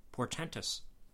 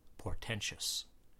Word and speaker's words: portentous: They are, in a word, portentous. Not portentous. The word portentous ends in T-O-U-S, not T-I-O-U-S, but people often pronounce it and spell it as if portentous was the correct word.